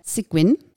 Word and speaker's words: Sigoigne: So with us today, we have Alexandre Sigoigne, who is the founder of Myposeo, which is an SEO analytics tool.